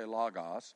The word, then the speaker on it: logos: So, we're going to, the word here comes from a Greek word that's, some pronounce it logos or logos. I'll say logos, L-O-G-O-S.